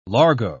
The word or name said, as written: largo